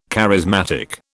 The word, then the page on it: charismatic: British English